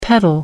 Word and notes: petal: American English

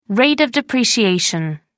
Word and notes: rate of depreciation: American English

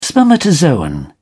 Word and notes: spermatozoon: British English

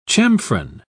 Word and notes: chamfron: British English

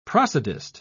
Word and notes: prosodist: British English

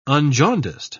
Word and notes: unjaundiced: British English

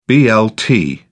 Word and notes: blt: British English

